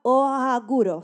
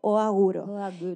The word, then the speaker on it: Ohaguro: El acto era conocido como, era una práctica que se llamaba Oaguro, Se escribe O-H-A-G-U-R-O. Oaguro.